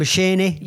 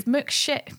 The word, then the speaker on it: McCheyne: I think it's McChain, but I say McShane, so it's spelled McChayne. So I'm pretty sure, I'm 99% sure.